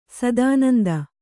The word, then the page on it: ಶತಾನಂದ: Kannada